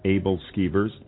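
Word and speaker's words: æbleskiver: Abel Skever, yes. Now, my wife would probably pronounce it a little differently than I would, but that's the American translation.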